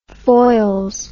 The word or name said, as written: foyles